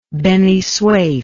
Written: Beni Suef

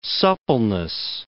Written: suppleness

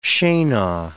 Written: scena

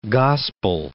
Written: gospel

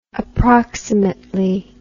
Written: approximately

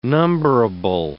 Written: numberable

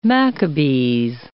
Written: Machabees

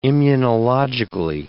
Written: immunologically

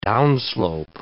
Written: downslope

